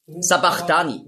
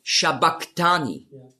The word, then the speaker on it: Shabachthani: Sabachthani lesen viele. Aber das heißt eigentlich auf Aramäisch ausgesprochen ist es Shabachthani. Und das ist das CH, das Chi, ist im Griechischen als K ausgesprochen worden. Aber das S steht eben für den Sch-Laut.